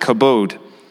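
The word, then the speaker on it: kabod (כבוד): And the thing about glory is that the word actually comes from the Hebrew word called, it's spelled kebed, but it said kabod.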